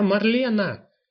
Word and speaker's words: Марлена: «Я Марлена! Мар-ле-на! Три слога, ударение на втором.